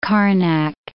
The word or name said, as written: Karnak